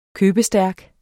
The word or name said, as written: købestærk